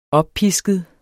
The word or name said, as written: oppisket